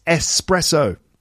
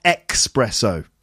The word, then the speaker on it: espresso: It's espresso, not expresso.